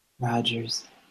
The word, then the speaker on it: Rogers: Okay, and Derek, is that D-E-R-R-I-C-K? – That's D-E-R-E-C-K. – D-E-R-E-C-K? – Yeah. – Okay, and your last name? – Rogers. – I'm sorry, can you spell that? – That's R-O-G-E-R-S. – I'm sorry, one more time? – R-O-G-E-R-S. – R-O-G-E-R-N? – Rogers, R-O-G-E-R-S.